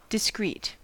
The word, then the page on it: discreet: American English